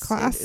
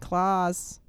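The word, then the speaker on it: Claes: No, it's not Klaus. – Oh. – It's C-L-A-E-S, and I did the pronunciation thing, and yeah. It's Klaus. It's like Klaus.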